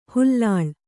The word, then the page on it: ಹುಲ್ಲಾಳೆ: hullāḷe